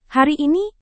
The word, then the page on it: hari ini: Indonesian